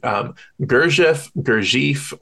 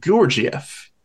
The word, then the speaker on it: Gurdjieff: Different people pronounce it differently. You can pronounce it Gurdjieff, or if you want to be like more authentic to the sort of Armenian Turkish pronunciation, it's more like Gurdjieff.